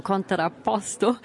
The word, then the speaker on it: Contrapposto: Ja, du hast ihn auch so wunderschön italienisch gerade ausgesprochen, Contrapposto. Und auch wir beide, Lukas, stehen hier auf einem Standbein und auf einem Spielbein.